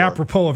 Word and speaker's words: apropos: A-P-R-O-P-O-S. – Yes. P-O-S. – S is silent? Like in Des Moines? – Yes.